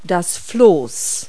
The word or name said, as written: raft